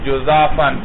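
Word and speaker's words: جزافًا: جزافن کا معنی جزافن جزافن جزافن، مثلث الجیم ہے مولانا،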